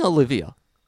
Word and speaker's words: Olivier: It's fucking Olivia. His surname is spelled O-I-L-I-V-I-E-R. That is not Olivier in any fucking sense of the word. Same with Ralph Fiennes.